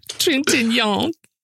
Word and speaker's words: Trintignant: Trintignant.